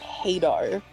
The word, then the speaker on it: Hadar: Is that how you say it? I've been saying Hadar for so long. I believe it's. I might be wrong. I believe it's Hadar.